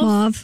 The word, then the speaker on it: mauve: And by the way, you do pronounce it mauve.